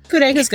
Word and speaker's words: Codega: Codega's good.